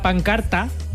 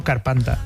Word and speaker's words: pancarta: Sacaron una pancarta. Mira, lo he dicho bien, siempre digo carpanta. – Ah, es verdad, claro. – Que sacaron una carpanta que podía leerse.